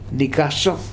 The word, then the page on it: nikasof: Futuna